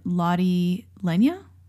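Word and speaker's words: Lotte Lenya: It stars Robert Shaw and Lottie Lenya. – I believe that's how you say her name.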